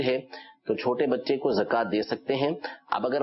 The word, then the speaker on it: زکات: یعنی یہ جو واو کے اوپر کھڑا زبر لگا ہوتا ہے، اس کو کھڑا زبر کاف کے اوپر پڑیں گے، زکات، زکوات نہیں. تو یہ تلفظ ٹھیک کر لیجئے.